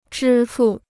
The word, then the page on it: 支付: zhī fù